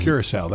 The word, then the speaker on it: Curaçao: Curaçao, that's how you say it.